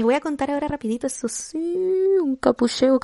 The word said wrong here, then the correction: copucheo: capucheo. No, ¿cómo se dice? Copucheo, copucheo, copucheo.